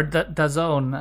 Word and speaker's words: DAZN: Yeah, so I mean, DAZN are already, or DAZN, as Boaz tells me it's actually pronounced in Italy, have already been a partner of Serie A for a while.